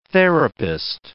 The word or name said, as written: therapist